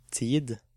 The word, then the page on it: tid: Norwegian